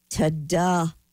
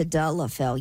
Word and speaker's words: Tadalafil: The drug's called Tadalafil. – That's the name of Cialis? – Tadalafil.